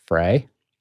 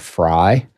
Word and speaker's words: Frey: So you can pronounce it either way. My grandfather was a French immigrant to America from Alsace. And in Alsace, it is pronounced Frey. But when he came to America, he moved to Chicago, and everybody there said Fry.